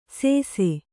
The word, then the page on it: ಸೇಸೆ: Kannada